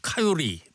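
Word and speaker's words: coyote: 이 코요테에도 저 영어 발음은 카요리 뭐 이렇게 발음하잖아요. 예. 우리는 코요테.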